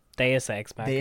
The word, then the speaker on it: Deus Ex Machina: Just take you back to the phrase you used, Deus Ex Machina. – How do you pronounce that? – Deus Ex Machina.